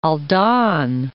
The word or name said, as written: Aldan